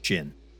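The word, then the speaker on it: Djinn: And you don't have to say Jin. You just say Jin. The D is silent.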